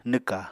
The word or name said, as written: nwka